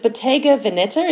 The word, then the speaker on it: Bottega Veneta: I will defer to you for your critique of this, which is basically is it Bottega Veneta? Is that how I pronounce this fashion house? – I think so, yeah. – Bottega Veneta. – Bottega Veneta,